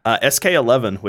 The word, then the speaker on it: SKXI: SK11, which is spelled SKXI.